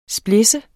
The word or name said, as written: splidse